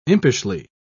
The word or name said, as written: impishly